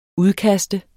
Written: udkast

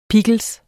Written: pickles

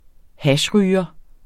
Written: hashryger

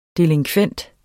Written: delinkvent

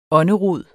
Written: ånderod

